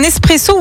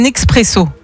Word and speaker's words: espresso: Alors, tiens, est-ce que tu dis un espresso ou un expresso? – Pour le café? – Oui. Ouais. – Espresso. – Espresso, ouais. Étymologiquement, on ne prononce pas le X. L'origine du mot vient de l'italien esprimere.